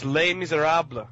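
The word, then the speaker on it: Les Misérables: It's Les Miserables.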